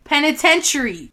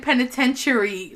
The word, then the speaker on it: penitentiary: We're saying penitentiary, like well, penitentiary. Yeah, wrong. – It's Penitentiary.